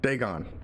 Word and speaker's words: Dagon: So other than Dragon? Dorgan. What's the pronunciation? – Dagon. Dagon.